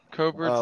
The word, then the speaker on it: Koppert: Copert's a Michigan-based insect grade. Is that Copert? – Yeah. – Copert, spell it for me. – K-O-P-P-E-R-T.